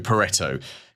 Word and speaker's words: Pareto: It's also known as the Pareto Principle, named after Vilfredo Pareto.